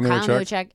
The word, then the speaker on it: Newacheck: Okay, Kyle Newacheck. If you're Polish and know how to spell that. – Yeah, it's phonetic. It's very phonetic.